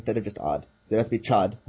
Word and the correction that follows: chud: not odd. Is what that's saying? – It has to be chud, yes. – Chud, yeah, sorry, I mispronounced that.